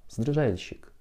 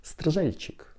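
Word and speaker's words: Стржельчик: Это Ульянов, Кваша, Табаков, Ефремов, Папанов, Евстигнеев, Лавров, Стрижальщик. Извините, еще раз тренируйся. Стрижальщик, вот так надо говорить. Мы говорили просто про Стрижальчик часто, говорили, потому что невозможно, польская фамилия для произношения.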